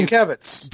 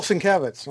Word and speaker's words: Sienkiewicz: We're like, how do you pronounce Bill Sienkiewicz? – Right, how do you pronounce that? – He's like, oh, Bill Sienkiewicz.